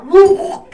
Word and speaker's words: Louisville: Now, located in Louisville, you gotta say it right, Louisville, Kentucky, or Kai, this facility is a prominent producer of Braille and large print textbooks and magazines and talking books.